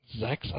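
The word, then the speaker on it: Sechs: It's pronounced ZX. – Zex? I thought it was ZX. – No, like, it sounds Zex. – The number 6. – No, like sex, but with a Z.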